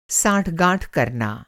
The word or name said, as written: साँठ-गाँठ